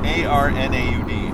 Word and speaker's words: Arnaud: Like, and that bass player for Saint, oh man, I, I want to say it right. Arnold. A R N A U D. – Okay, Arnaud, Arnaud.